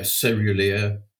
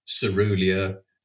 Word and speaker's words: caerulea: Well, almost certainly from the description, it's Passiflora caerulea, which uh, is a tricky word because there's many different pronunciations of it. You can also pronounce it uh, cerulea, cerulea, and uh, all the pronunciations are correct.